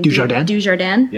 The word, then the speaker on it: Dujardin: watching Charlotte, how do you say her last name? – Dujardin, yeah.